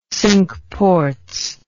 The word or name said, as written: Cinque Ports